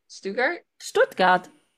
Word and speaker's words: Stuttgart: I always say the name wrong because I don't think I was ever told how to say it correctly, but Stuttgart? – Stuttgart. It's 1 hour from here, Stuttgart.